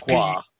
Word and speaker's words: pourquoi: Since the French for what is French for why, it, and I'm going to butcher this. Bring the butcher this, it's pourquoi. – That's pourquoi. – Pourquoi, thank you.